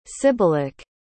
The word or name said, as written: sibylic